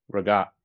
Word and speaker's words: ricotta: Regat. Regat. Also, yeah, regat is how you say it. That's how you pronounce it.